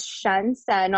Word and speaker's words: Qiancen: My name is Shen Sen on LinkedIn. So you can connect with me. It's spelled Q-I-A-N-C-E-N.